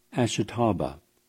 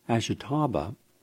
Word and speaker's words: Ashitaba: Many Japanese elders refer to the plant, and I'll spell it for you, Ashitaba, A-S-H-I-T-A-B-A, as the immortality plant, and believe it will keep you looking and feeling young.